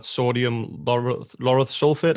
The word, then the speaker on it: sodium lauryl sulfate: the most common one normally is that sodium lauryl sulfate. Am I pronouncing that right? – Yeah.